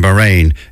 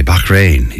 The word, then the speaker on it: Bahrain: Now, I was in Bahrain before, and I'm saying it like that because apparently saying Bahrain is wrong. And they tell you, no, that's not how you say it. You say Bahrain. You put a big in the middle of it.